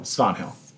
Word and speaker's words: Svanhill: Wait, is it Svanhill or Svanhill? – Svanhill. – Spell it, please. – S-V-A-N Hill.